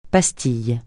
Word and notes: pastille: francais

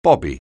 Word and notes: bobby: anglais

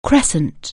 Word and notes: crescent: anglais

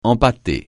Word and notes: empâter: francais

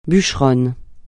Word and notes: bûcheronne: francais

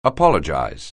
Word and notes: apologize: anglais

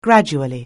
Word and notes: gradually: anglais

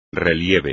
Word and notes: relieve: espagnol

